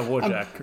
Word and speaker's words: Wojtek: Yeah, Wojtek. – I'm probably butchering that pronunciation. – It's Polish, so yeah. – Oh, yeah, Polish, yes. Did I say that? – Yes. – Yeah, you did.